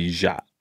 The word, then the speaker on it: Ya: My name in Hmong is actually Ja. – And so they couldn't pronounce it, so whoever was there was just like, all right, let's just do Ya, Y-A, and be done with it.